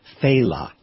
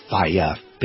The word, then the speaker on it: Failla: His name is pronounced Faila. Faila. It's spelled F-A-I-L-L-A. So people go Failia, Failia. You know, but it's Faila.